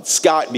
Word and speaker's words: Scott: And so across the room, my friend Samuel and another friend named Daniela, they said, hey, Scotchy, that's how you say my name in Portuguese. The T at the end of the word becomes a CH sound. So Scott becomes Scotch, and then they always put E at the end of things.